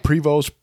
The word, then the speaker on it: Prevost: Theodosia, who is the girl that Burr is talking about, her husband was Jacques Marcus Prevost. I probably did not say that correctly at all. – Yeah, it's like Prevost, Prevost. It's P-R-E-V-O-S-T.